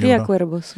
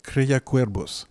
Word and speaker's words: Cría Cuervos: Je ne sais pas si je le dis bien. Le V, on dit bien B en espagnol. – Cría Cuervos, oui. – Cría Cuervos.